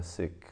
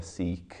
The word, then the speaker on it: Sikh: Sikh way of life. And you don't have to be a Sikh, that's not the correct pronunciation, but just so you know, I'm not saying Sikh like have a cold, you know.